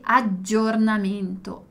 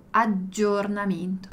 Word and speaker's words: aggiornamento: Os dois Gs também é a gente dá aquela demorada, né? Então guarda quando dico giorno, um G, giorno e aggiornamento, aggiornamento. Sempre uma pronúncia um pouquinho mais demorada qua delle doppie consonanti.